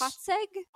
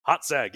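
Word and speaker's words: Hațeg: Hatzeg? – Hatzeg, yes. – H-A-T-E-G.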